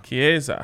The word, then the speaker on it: Chiesa: Chiesa.